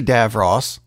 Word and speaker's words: DeVos: Speaking of ignorance, Education Secretary Betsy DeVos, yeah, I'm pronouncing it right,